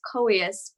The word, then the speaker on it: Coeus: How do you pronounce it? And of course, it's Coeus. You know, we say it on the show all the time.